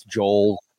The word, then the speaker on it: Joel: My full name is Joel Robertson, but I've been called Joe Robinson. Joel. I get Joel a lot. I guess it's a pronunciation thing. Some people say Joel. It's Joel, like Noel, or some people say Noel.